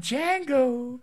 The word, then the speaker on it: Django: I'm Django. The D is silent.